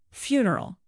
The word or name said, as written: funeral